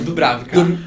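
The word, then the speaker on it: Dubravka: Ex exemplo, o, o Drubávica, né? o Dubrávica.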